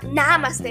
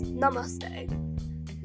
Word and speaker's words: namaste: It's not namaste, it's namaste.